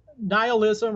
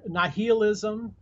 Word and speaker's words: nihilism: Uh, would you explain nihilism? Did I say that right? – Yeah. Uh, nihilism or nihilism